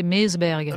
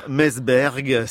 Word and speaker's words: Meseberg: Je ne sais pas si je le prononce bien, Natacha. – Mon avis, ça doit se prononcer Meseberg.